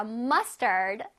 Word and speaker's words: mustard: Mustard. Mustard. 자, 이것도 마찬가지로 앞부분에 조금 뚜렷하게 발음해줘야 돼요. M-U-S-T. Must 한 다음에 A-R-D는 약하게.